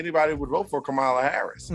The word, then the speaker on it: Kamala: So I do want to make a slight correction because I was joking at first, but just so we know, it's Kamala. That's how you pronounce it.